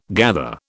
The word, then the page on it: gather: British English